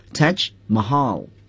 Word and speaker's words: Taj Mahal: 接下来呢，请大家看课文 The Taj Mahal 这个题目的读法。 – The Taj Mahal 这个词呀，泰姬陵这个词呢，它严格意义上讲有那么一点印度语在里面。 Taj Mahal 是用英语给它拼出来了，正确的发音呢读作 Taj Mahal Taj Mahal。